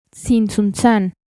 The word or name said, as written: Tzintzuntzan